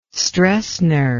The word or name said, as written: Stroessner